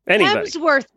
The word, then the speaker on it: Hemsworth: Any of the Helmsworths, you can call too. Anybody. – Hemsworth. – Hemsworth. Why do I always say Helmsworth? – Because you mispronounced the name.